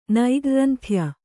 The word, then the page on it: ನೈಗ್ರ್ರಂಥ್ಯ: nairgranthya